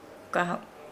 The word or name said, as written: gaho